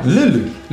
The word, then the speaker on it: Lille: É isso a pronúncia? – Lille. – Lille. Lille é o biquinho, o biquinho.